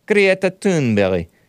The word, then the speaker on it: Greta Thunberg: That's the Swedish rough pronunciation of Greta Thunberg. Greta Thunberg.